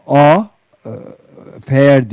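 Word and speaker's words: FAIRR: Ee, yani dünyanın en büyük e, yatırım e, ağlarından bir tanesi olan Farm Animal Investment Risk and Return diye bir network yani şey A fair diye okunuyor 2 R ile dünyada en büyük yüzde yetmiş üçünün e,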